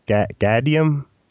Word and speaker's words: Gaudium: I've been pronouncing his name wrong, but it's pronounced Gaudium. – Gaudium, yeah. – I think I've been saying Gaudium. – It's the Latin word for joy.